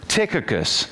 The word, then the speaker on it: Tychicus: Tychicus. Go back to Colossians now and you'll see this. Go back to Colossians chapter 4, verse number 7. The CH is pronounced like a K.